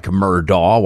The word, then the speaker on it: Murdaugh: It looks like Murdaugh. Why the family pronounces it Murdaugh, I can't help you understand, but they do.